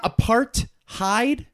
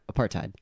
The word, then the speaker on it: apartheid: Apartheid. So what you're saying is apartheid? – Apartheid. – It's not apartheid? – No, apartheid. That is the cadence of the word, apartheid.